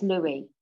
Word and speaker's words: Luis: What are we saying? – It's Louis. Yeah. – Have you seen Louis. How it's spelled? – Yeah, it's spelled. Yeah. – L-U-I-S.